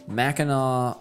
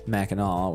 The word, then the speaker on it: Mackinac: It's spelled that way, but that's like the Native American spelling. And so some places are called Mackinac, which is spelled with an A-W. Which is spelled how it sounds, which is like when the English settlers came in, they called it that or spelled that way. And then some places you'll find the AC ending, which is the silent C.